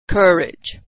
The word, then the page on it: courage: American English